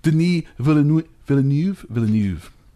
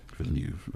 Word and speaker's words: Villeneuve: He did one with, now I guess I know how to say it properly, Denis Villeneuve. – Okay. – Villeneuve.